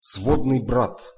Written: svodnyj brat

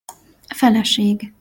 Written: feleseg

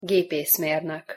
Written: gepeszmernok

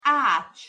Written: acs

